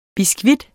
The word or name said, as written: BISKUITTER